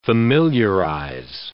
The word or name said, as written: familiarize